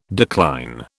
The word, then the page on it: decline: British English